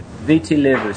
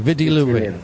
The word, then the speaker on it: Viti Levu: Viti Levu. So it's spelled V-I-T-I. – Yeah, okay. Viti Levu.